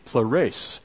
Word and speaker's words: pleres: The transliteration, the pronunciation of it in the English would be pleres.